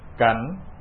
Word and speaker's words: kính: Hoa cảnh, chữ này cũng đọc là kính nữa.